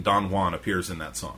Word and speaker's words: Don Juan: DJs, dirty joke, desk jobs, Don Juan, or is it Don Juan? – I think it's, the J is silent.